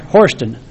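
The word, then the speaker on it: Harston: and the fellow's name was George Horston. G-E-O-R-G-E-H-A-R-S-T-O-N. George Horston is how we pronounce it.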